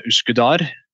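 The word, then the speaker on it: Üsküdar: Så da tok jeg ferga over til eh, dette Uskudar. – Ja. – Eh, det uttales vel omtrent, omtrent, slik? Omtrent sånn, ja.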